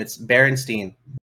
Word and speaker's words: Berenstain: It's Berenstain.